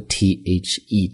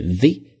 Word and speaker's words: the: 注意中间的 in the office，这个地方的 THE 读的是 the 不是 the，因为后面的 office 是一个元音发音开始的一个单词。